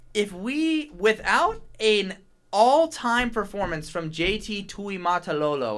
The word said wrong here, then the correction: Tuimoloau: JT Tuimoloau.